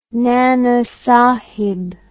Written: Nana Sahib

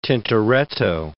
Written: Tintoretto

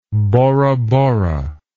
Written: borabora

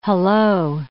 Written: hello

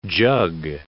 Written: jug

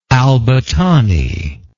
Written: albattani

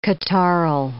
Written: catarrhal

